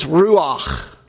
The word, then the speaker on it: ruach: ruach. Ruach. If you're going to say it like a good Jew. You got to use a little phlegm.